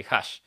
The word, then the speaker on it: Hush: Vayan a buscar H-U-S-H, se escribe Hush. Así, Hash.